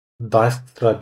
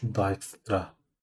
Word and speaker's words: Dijkstra: Dijkstra diye okunuyormuş. – Dijkstra. – Dijkstra.